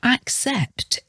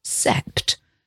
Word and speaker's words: accept: So some examples of double C pronunciation: succeed or accept, A-C-C-E-P-T. So again here, that first C is hard, ack, and the second C is soft, accept. So we say accept. It's almost as though it should be spelt A-C-K-S-E-P-T.